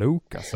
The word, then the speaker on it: Lucas: Max Puls-segmentet ska nu inledas med Lucas Raymond. Jag hade lite ett engelskt uttal där på Lucas, kände jag. Lucas, ja.